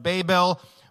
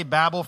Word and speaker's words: Babel: And so Genesis 11, if you don't know, I'll give you a spoiler, it's about the Tower of Babel, and there some people pronounce it Babel, Babel. I'm going to say Babel for the sake of that's what I grew up saying.